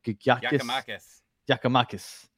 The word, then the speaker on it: Giakoumakis: I forget how to even pronounce his name. – Giakoumakis. – There you go. – You brought him up. Forget him now. – All right. Giakoumakis or whatever.